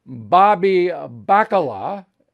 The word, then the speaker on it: Bobby Bacala: And then I contrast you to Bobby Bacala.